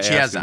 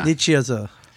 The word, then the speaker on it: Nicieza: Nicieza. – Nicieza.